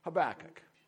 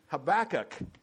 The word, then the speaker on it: Habakkuk: And it's probably one of the most ill-pronounced words, Habakkuk. I called him Habakkuk when I was when I was growing up.